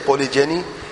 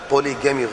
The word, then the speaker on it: پولی جینی: اگر یہ معنی ہے, میں یہ جانتا تھا ہاں پولی جینی یا پولی پولی پولی جینی جو لفظ ہے وہ صحیح ہے, پولی جینی, کہ ایک مرد ایک سے زیادہ عورت سے شادی کر سکتا ہے. تو یہ مجھ سے تلفظ میں غلطی ہوئی, اللہ تعالیٰ مجھے معاف کرے. اتوب الیہ واستغفر اتوب الیہ. اور جس نے یہ غلط پیغام سنا ہے وہ اپنے اصلاح کر لیں. پولی گیمی غلط ہے, پولی جینی ٹھیک ہے.